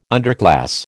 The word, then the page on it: underclass: American English